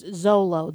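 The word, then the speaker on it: Xolo: Yeah, but it's pronounced Zolo.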